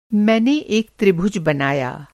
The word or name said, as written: त्रिभुज